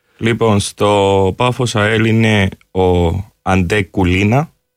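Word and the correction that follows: Čulina: Ναι. Τσουλίνα, να δείξω πώς προφέρεται. – Τσουλίνα. – Τσουλίνα. – Ναι. Αντέ Τσουλίνα, ναι.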